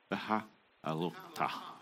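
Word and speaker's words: Behaalotcha: This is a Parashat Behol. Oh, I can say this because I've been... Behalotha. Right. Better pronunciation, yeah.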